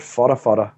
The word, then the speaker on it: Whatawhata: I ran one down at a place called Whatawhata, which is Whata. That's how they pronounce it.